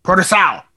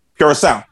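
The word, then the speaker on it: Puroresu: So, I'm saying it wrong. Is it Puro Wrestle? – Purosao. – Purosao.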